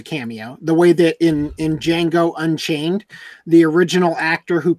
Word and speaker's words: Django: In the scene where Jamie Foxx is telling that guy the G is silent, the D in Django is silent, and the guy goes, "I know." That was a nod to the fact that guy played Django in the original movie.